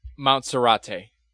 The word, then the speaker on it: Monserrate: So then I was watching it, and the company that this was taking place in was called Montserrat. – Okay. – If I'm pronouncing that correctly. Am I pronouncing it correctly? – Montserrate. – Montserrate. – A la montaña de Serrate. That's the Spanish pronunciation.